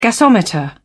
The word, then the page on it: gasometer: British English